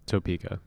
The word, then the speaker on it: Topeka: It's called The Topeka School, as in Topeka, Kansas. Is that how you say it? Topeka? Mm-hmm. – Topeka. – Topeka. – Yeah, you got it. You got it the first time.